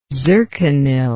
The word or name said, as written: zirconyl